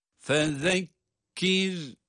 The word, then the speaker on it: fezekkir: Fezekkir, fezekkir, zal peltek, peltek okunuyor zaten. Fezekkir, sonra da cezimli olan ra, kendisinden önce esri olması itibariyle ince okunuyor.